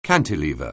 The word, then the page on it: cantilever: anglais